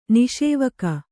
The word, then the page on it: ನಿಷೇವಕ: kannada